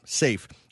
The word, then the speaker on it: Saiph: Safe is a star, and it's spelled S-A-I-P-H. Safe.